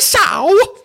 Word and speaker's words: Sow: Not only because his name rhymes with cow, but he also used to play ice hockey and loves the Montreal Canadiens. Saidi Sow!